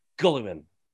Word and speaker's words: Guilliman: Guilliman. I know that's not how it's pronounced.